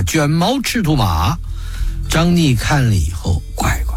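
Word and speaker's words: 张嶷: 为首的蜀将乃是张逆，以前介绍过的，这个字有两个读音，一个念逆，一个念疑，上边一个山字，下边一个怀疑的疑，张逆。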